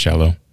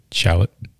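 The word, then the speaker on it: Shallotte: I think it's pronounced shallow. That's what Justin, the manager, said after you had meant some. After you guys had said shallot, he said shallow.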